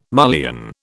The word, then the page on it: mullion: British English